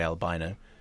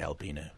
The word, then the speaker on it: albino: The deal on that is, because if you look up for pronunciation, it says either is acceptable. However, what happens is the Americans say albino, British people say albino.